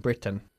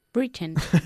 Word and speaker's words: Britain: ¿Cómo pronunciamos nosotros Britain? – Britain. – Muy bien. Has estado muy atenta. Decimos Britain y no Britain.